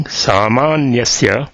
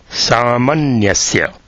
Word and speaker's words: Samanyasya: So this is to be noted that the reading Samanyasya is not correct. It should be Samanyasya. The second syllable is short, not long.